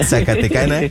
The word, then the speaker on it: Zacatecana: Linda, la Zacatecaneca, ¿cómo se dice? – Zacatecana. – La Zacatecana, sí.